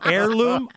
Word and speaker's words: heirloom: So what a great use of that family heirloom. I'm sorry, that's a silent H. That's heirloom.